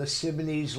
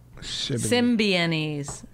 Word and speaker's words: Symbionese: Alexa, was Joanne Chesmart a member of the Sibonese Liberation Army? – He won't say it correctly. – Sorry, I don't know that one. – It's Sibonese. – Symbionese.